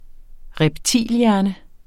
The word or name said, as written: reptilhjerne